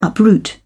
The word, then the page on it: uproot: British English